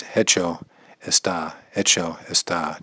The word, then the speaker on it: hecho está: It is done is hecho está, hecho está. It's silent H, I believe. Hecho está, it is done.